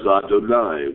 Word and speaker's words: Adonai: The reader is told to pronounce the first as Adonai, which is the usual manner to pronounce Yud-Kei Vav-Kei whenever it is seen in the Torah or the Tanakh scrolls.